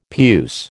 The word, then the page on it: puce: American English